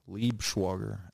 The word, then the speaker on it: Liebschwager: Dalton, D-A-L-T-O-N, Liebschwager, L-I-E-B-S-C-H-W-A-G-E-R.